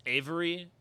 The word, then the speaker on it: Aervy: How to say this name? A E R V Y. – Oh yeah, it's Avery.